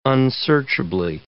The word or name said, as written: unsearchably